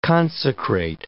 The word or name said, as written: consecrate